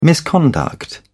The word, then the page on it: misconduct: British English